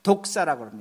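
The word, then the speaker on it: 독사라고 (δόξα): 헬라어로 영광을 독사라고 합니다. 아주 이해하기 쉽습니다. 우리 뱀 독사, 그 독사하고 똑같이 발음하시면 됩니다.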